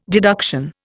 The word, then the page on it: deduction: Indian